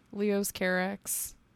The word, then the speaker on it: Leos Carax: Leos Carax. That's how Adam Driver said it on the video where he lost his dog.